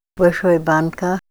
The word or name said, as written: Big Can